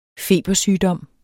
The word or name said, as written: febersygdom